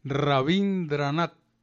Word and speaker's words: Rabindranath: Se escribe Rabindranath con B alta y TH al final. Rabindranath.